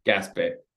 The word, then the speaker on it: Gaspé: There's the Bromont Ultra, and then there's one that I'm not sure how to say it. – Gaspé. – Gaspé? – Yeah, Gaspé, yeah.